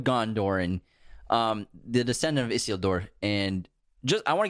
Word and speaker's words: Isildur: Yeah, he is nobility because he's the descendant of Isildur, and he's the Isildur. – True king. Isildur, sorry. What do I keep saying? – Isildur. – Isildur. Isildur. – Say it like it's Spanish. Isildur.